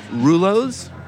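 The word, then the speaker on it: Rullo's: Rulo's. – Spell it. – R-U-L-L-O and then apostrophe S.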